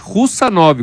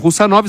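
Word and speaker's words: Khusanov: É escrito Hussanov, mas a pronúncia, o jeito que eles falam é Hussanov, como se eles omitissem esse K. Então, Hussanov, zagueiro, que inclusive é um zagueiro bastante promissor, 22 anos, e ele foi comprado pelo City.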